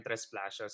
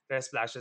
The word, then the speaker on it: Tresplacios: It's really always at Ryan Tresplasho. So my surname goes Tresplasho.